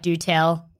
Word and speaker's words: Dotel: The dotel is pronounced like do-tell. So I'm going to do-tell you about a hotel.